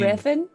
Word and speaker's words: Revan: Did I say it wrong? Fuck me. – Is there... – You mean Revan? – Revan, oh, motherfucker. I'd Revan. Like to go. I'd like to go to Revan.